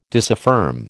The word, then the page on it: disaffirm: American English